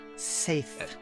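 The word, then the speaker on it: Saeth: It's less complicated than what you did, but yes, it's pronounced safe. – Got it. Safe Saf? With a. – No, safe. There's not a lot of leeway in how that's pronounced.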